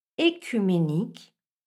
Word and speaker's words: œcuménique: Mais attention, cette première syllabe, qu'elle s'écrive E accent aigu ou E dans l'eau, doit se prononcer avec un E fermé. Il faut donc dire Œdipe, œcuménique ou œsophage.